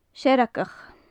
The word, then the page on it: searagaich: gaelic